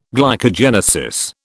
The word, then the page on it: glycogenesis: British English